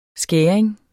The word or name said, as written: skæring